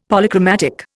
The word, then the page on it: Polychromatic: Indian